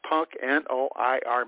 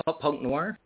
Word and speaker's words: Noir: But I looked up a few poems, I'm looking at, I don't even know how to pronounce this, Punk N-O-I-R magazine. – Oh, Punk Noir? – N-O-I-R.